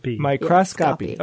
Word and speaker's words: Microscopy: Microscopy. Okay. – Two hard C's.